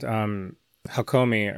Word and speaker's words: Hakomi: You mentioned Hakomi earlier. Am I saying that correctly? – Yeah, that's right.